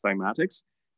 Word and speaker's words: cymatics: It's spelled C-Y-M-A-T-I-C-S, oh. Cymatics.